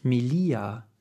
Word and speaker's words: Melia: Und äh, sie heißt Melia. Und das hat sie mir dann geschrieben, hat gemeint, das ist ja nett, dass du mich gegrüßt hast, aber die Betonung liegt auf dem I.